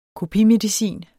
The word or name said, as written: kopimedicin